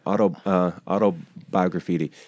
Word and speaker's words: Autobiograffiti: So you have you talked a bit about it, but you have a new album coming out later this year, Autobiographity. Did I say it right? – Yeah, well, yeah, it's a mouthful. Autobiographity.